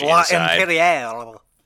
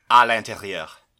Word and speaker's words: À l'intérieur: This is the American remake of, I'll let you pronounce it in French, but the French L'intérieur. – À l'intérieur. – Yeah, that one. – I find we both said it correctly.